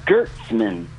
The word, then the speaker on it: Gertzman: His name, Maury Gertzman, it's spelled with an S, but he always insisted on having it with a Z, Gertzman, on the credits.